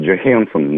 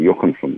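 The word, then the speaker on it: Johansson: Или Скарлетт Джохансон, на самом деле, а не Йоханссон и так далее.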